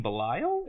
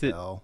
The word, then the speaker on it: Bel: What word are you saying? Belial? – Is it Belle? – It's Belle. – How do you spell it, Josh? – I thought it was B-E-L. – It's just B-E-L. – Yeah, I thought it was B-E-L.